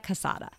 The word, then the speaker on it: casada: So it's the only difference is an N, cansada, casada.